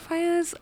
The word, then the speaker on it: Faiyaz: Faiyaz.